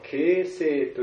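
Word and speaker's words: 傾城: でそれが今日本に中国のことわざの言葉が日本に入りまして、城を傾けるという言葉がですね、城を傾ける、それを漢字で書いて、形成と読むんだそうです。形成、形というのは傾けるですね、成というのは城という字を書きますけれども、形成という言葉が、でそれは良い意味にも使われますし悪い意味にも使われまして、良い意味というのは形成というのは絶世の美女のことを指すんですね。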